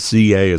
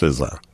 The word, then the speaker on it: SZA: How would I know that SCA is pronounced SZA? – I wouldn't have known.